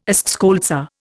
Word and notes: eschscholtzia: Indian

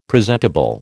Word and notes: presentable: American English

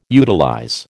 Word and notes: utilize: American English

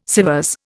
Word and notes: Sèvres: Indian

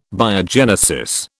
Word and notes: Biogenesis: British English

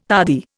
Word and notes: tardy: Indian

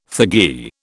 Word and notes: thuggee: British English

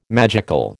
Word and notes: magical: American English

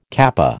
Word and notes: kappa: American English

